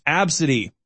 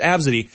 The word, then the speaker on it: ABCDE: It's A-B-C-D-E, just the letters A-B-C-D-E. Phonetically, it's pronounced Absidy. Phonetically, the name A-B-C, it's spelled A-B-C-D-E, it's pronounced Absidy.